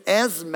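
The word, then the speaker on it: Esmé: Her name is Esme, and it's spelled E-S-M-E, with a little accent on the E, E-S-M-E, and everyone has just really butchered that one. They'll just go, Esme? And we'll say, yeah, that's me. Esme.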